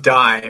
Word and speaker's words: Dai: Well, my name is pronounced Die. for those that are probably looking at that and thinking, what is that? And uh, it's a Welsh name, means David.